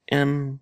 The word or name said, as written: em